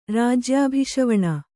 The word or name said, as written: ರಾಜ್ಯಾಭಿಷವಣ